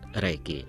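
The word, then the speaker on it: Рейки (霊気): Но против всего напоминаю, что эти два иероглифа «Рейки» произносятся как «Рейки» только по-японски, а в китайском языке их произношение «Линчи», но они имеют одинаковый смысл.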